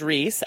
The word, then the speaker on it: Rhys: I know one other guy named Reese, and it's spelt like this. R-H-Y-S.